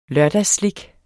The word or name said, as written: lørdagsslik